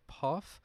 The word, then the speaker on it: Paugh: Ashley, I believe her last name is pronounced Pauff, P A U G H.